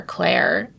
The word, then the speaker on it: Clare: but yeah I'm at Maya underscore Clare.